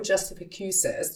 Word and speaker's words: Justificuses: No Justificuses.